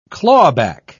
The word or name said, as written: clawback